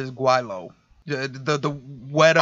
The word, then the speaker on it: gweilo: Yo, and I mean, like that because now I can sit there and say gweilo.